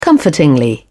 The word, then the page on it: comfortingly: British English